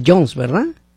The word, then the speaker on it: Jones: Sí, quítenle como la Juanes, pero Jones.